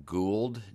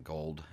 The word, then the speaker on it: Gould: Yeah, I realized last week after I heard the show back, the music was composed by Morton Gould, G-O-U-L-D, not Gold. I mispronounced it.